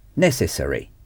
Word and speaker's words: necessary: En anglais, la terminaison R est hurry. Au lieu de dire R, on dit hurry. Hurry s'écrit A-R-Y. Par exemple, nécessaire. Necessary.